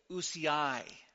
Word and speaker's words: ousiai: And the plural is ousiai. See, look at that phonetics. Did it all by myself.